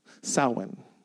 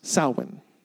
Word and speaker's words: Samhain: But based on the research that I did, you have to go back to ancient Celtic festival known as Samhain, okay? It's spelled Samhain. But it is pronounced Samhain.